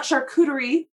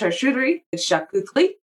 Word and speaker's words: charcuterie: Which, oh, by the way, it's not charcuterie, it's charcuterie.